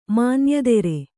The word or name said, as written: ಮಾನ್ಯದೆರೆ